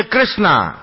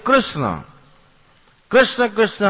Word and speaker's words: कृष्ण: नाम में भी हमारे आचार्यों की जब आप टीकाएं पढ़ेंगे, जैसे कृष्णा, कृष्णा नाम द्रौपदी का है, हरे कृष्ण कृष्ण कृष्ण हरे हरे, हरे राम राम राम हरे हरे, शुद्ध उच्चारण है।